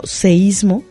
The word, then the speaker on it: seísmo: Porque la semana pasada, si no mal recuerdo, fue justamente cuando planteaste la expresión, el término seísmo, en lugar de sismo. – Así es correcto, fue la semana pasada. – Que es correcta su escritura y su pronunciación en otros países, ¿no? – Así es.